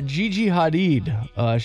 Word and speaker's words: Gigi Hadid: Model Gigi Hadid.